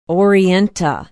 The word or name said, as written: orienta